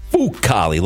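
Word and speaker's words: Fucale: It's Favalli and it's Fucali, like the Foo Fighters and the dog.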